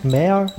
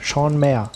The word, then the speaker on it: Maher: Ich war nämlich letztens auf der äh, FedCon 10 und da war auch Jean äh, Sean Maher. Heißt der so? Echt? Mayer? Mayer, Sean Mayer. Genau, man sollte, man sollte die Namen englisch aussprechen.